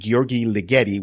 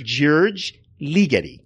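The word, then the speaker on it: György Ligeti: I think I've always been pronouncing it Gheorghe Ligeti, which I think is totally wrong. I think it's George, like George, but with George? – George Ligeti.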